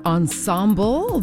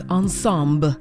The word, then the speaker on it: ensemble: It looks like the word ensemble, but since it is French and I kind of heard her saying ensemble, I'm going to say it like Gayanse does, ensemble.